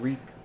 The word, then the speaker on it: Reek: Not you so much, but you, Andrew, Kevin, talking about Reek rhymes with this Meek.